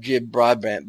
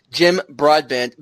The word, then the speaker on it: Jim Broadbent: But I like that you brought up Jim Broadbent, because, oh man, I just butchered his name, Jim Broadbent,